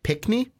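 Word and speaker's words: Pinckney: You got Ed Pickney, I believe is how you say it. – Correct.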